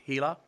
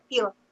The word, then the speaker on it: HeLa: The, correct me if I'm saying it wrong, the HeLa, H-E-L-A, is that how you say that stem line? HeLa? – HeLa.